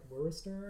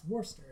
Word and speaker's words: Worcester: it's actually not pronounced, because we were all pronouncing it, because it's W-O-R-E-S-T-E-R. – Worcester. – Well, phonetically, I would read that as like Worcester or something. – Worcester, Worcester. – Yeah, and they were like, no, it's actually pronounced Worcester, and then they were like, you know, it's wicked hot, something.